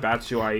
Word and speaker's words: Batshuayi: Yeah, I mean, I can't pronounce Batshuayi either. I mean, well, I just said it. Never mind. – Okay. You just said it quite nicely, yeah.